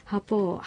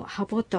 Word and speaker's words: ハポ: 日本語ですと柿と鍵は別の言葉ですが、アイヌ語では音が濁るかどうかで意味が変わることはなく、ハポをハボと発音してもよく、どちらも正しいのです。